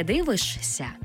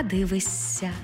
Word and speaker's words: дивишся: Наприклад, не «дивишся», а «дивися». Не «смієшся», а «смієся».